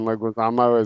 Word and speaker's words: Leguizamo: Uh, anyways, I was gonna say I thought the opening was pretty cool, and that that's John Leguizamo, right? Is that how you say his name? Yeah, John Leguizamo is the the mob boss.